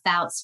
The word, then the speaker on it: Foutz: We actually have a page, The Fouts Family. So anywhere you type that in on social media, you should find us. And Fouts is spelled Foutz.